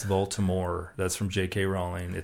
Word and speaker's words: Voldemort: It's French, so the T is silent. So, but in the movie, there's some of the people that say Voldemort, because that was written on the script. But it's Voldemort.